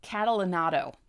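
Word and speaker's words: Catalanotto: Frank Catalanato. Hold on. Catalanato. There you go. I know how to say it.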